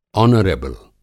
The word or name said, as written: आनरेबुल